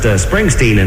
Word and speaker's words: Springsteen: Now, Mr. Law, the most compelling question we have for you is, does Mr. Springsteen wear glitter suits? – Sir, it's pronounced Springsteen, and no, he doesn't.